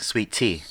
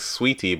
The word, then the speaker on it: Saweetie: It's like saying. – I think it like sweetie, but sweet. Oh, sweetie. – Makes sense. – Sweet tea. Sweetie. – Okay. Yeah, because it's spelled S-A-W. – I mean, if you're from Atlanta, it might be sweet tea.